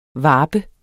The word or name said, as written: varpe